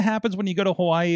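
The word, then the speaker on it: Hawaii (Hawaiʻi): By the way, did you did you know that in Hawaiian it's not actually pronounced Hawaii? Oh, oh really? Yeah, it's pronounced Hawaii.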